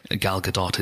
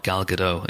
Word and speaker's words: Gal Gadot: hey, if there's anything that comes from this Oscars, it's that the world now knows how to pronounce Gal Gadot. It is not Gal Gadot, it's Gal Gadot, and uh, the strength of her personality walking into the room.